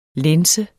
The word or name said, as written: lænse